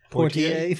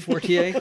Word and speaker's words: Poitier: The director was Sidney Poitier. – Poitier.